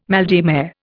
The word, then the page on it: mal de mer: Indian